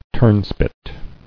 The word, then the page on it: Turnspit: turn·spit